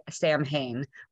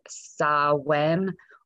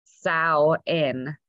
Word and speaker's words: Samhain: I say Samhain, but apparently that is not how to pronounce it. So I will tell you, you pronounce it Samhain or Samhain, like cow, Samhain.